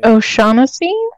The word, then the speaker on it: O'Shaughnessy: O'Shaughnessy? – There we go.